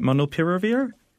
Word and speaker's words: molnupiravir: Molnupiravir. Is that how you say it? Molnupiravir? – That's close enough.